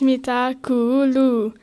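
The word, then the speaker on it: mitä kuuluu: Mita Kuulu. – No. It's Mita Kuulu. One letter is one sound in Finnish. And where does a double letter, all the letters are pronounced.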